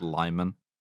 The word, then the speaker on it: Limon: Towns that have Spanish names that they pronounce Anglo, like Limon, which is pronounced Lyman.